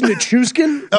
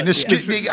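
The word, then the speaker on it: Nichushkin: I think it's Nishukin. – Okay, Nishukin. – There we go. – I'm going with the old school Russian pronunciation there.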